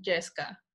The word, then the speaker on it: yesca: Well, it depends on the part of Mexico you're from, but from my state, you call it yesca. – How do you spell that? – It's Y-E-S-C-A.